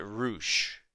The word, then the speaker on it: Rush: Where he writes it in all caps, R-U-S-H. – Oh, okay. – But they pronounce it Roosh.